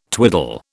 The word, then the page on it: twiddle: British English